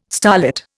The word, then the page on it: starlit: Indian